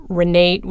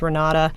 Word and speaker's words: Renata: And if you needed a date, it was late, you should call Renate was how they pronounced it, although it's Renata.